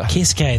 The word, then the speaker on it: Keisuke: Keisuke, that's it. Yeah, that's how you say it.